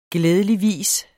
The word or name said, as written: glædeligvis